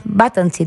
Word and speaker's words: Батанци: Тоест, можем да обобщим, че мини Груп Мадан, след придобиването им от Върба Батанци, консорциума Върба Батанци, Батанци даже, ударението, нали? – Да.